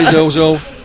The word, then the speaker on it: Carrizozo: But it was great when we stopped to a small town called, excuse the pronunciation, Carrizozo, on the way down. – It beats me. – Yeah, Carrizozo.